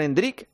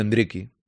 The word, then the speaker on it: Endrick: Sí, Melchor, Hay que decirlo así ya, ¿eh? Endriki. Ya está. Se lo dice Rodrigo, lo dijo también el otro día eh, Quique, ¿no? Hay que llamarle Endrick. O sea, o sea que ya pronunciarlo Endriki. eso ya me cuesta más, pero Endriki. Endriki, ya está.